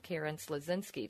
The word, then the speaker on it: Karen Slasinski: And we're here with Karen Slezinski.